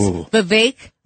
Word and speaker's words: Vivek: Yes, can I just say his name is Vivek Like Cake.